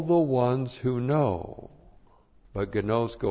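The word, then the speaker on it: gnosko (γινώσκω): Agapao and gnosko, G-I-N long O-S-K long O, gnosko.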